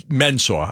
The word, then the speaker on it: Mensah: I'm making sure I pronounce it. It's Mensah. I know the kid. I'm pretty sure I got his name right.